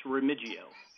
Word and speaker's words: Remiggio: It's Remiggio.